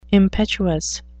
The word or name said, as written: impetuous